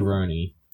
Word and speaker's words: Rurouni: Ronin Kenshin? – Rurouni Kenshin. – How do you spell that? – R-U-R-O-U-N-I.